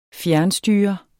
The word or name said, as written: fjernstyre